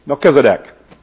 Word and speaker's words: Melchizedek: M-E-L-C-H-I-Z-E-D-E-K, Melchizedek. A lot of different pronunciations too, because it's pronounced a little bit different in Hebrew than what it is in Greek,